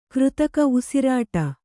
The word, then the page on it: ಕೃತಕ ಉಸಿರಾಟ: křtaka usirāṭa